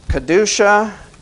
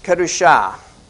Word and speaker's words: Kedusha: Kedusha. I listened to somebody say it wrong, so it's in my mind here. Kedusha.